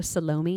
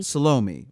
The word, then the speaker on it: Salome: Can you pronounce it, please? Because I can't. – Oh, well, they pronounce it in the show Salome. – Salome, right.